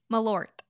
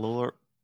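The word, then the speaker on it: Malört: Malort. – Yeah, you didn't pronounce that T nearly hard enough. – Malort.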